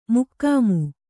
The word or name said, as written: ಮೊಖಾಮು